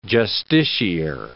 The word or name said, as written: justiciar